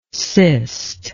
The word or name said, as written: cyst